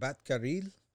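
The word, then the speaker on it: Batgerel: They were saying it Batkaril.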